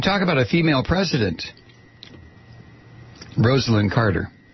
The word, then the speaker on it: Rosalynn: How do you say it? Is it Rosalynn? Rosalynn. That's right. It's Rosalynn Carter.